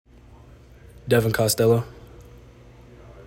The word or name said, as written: Devin Costello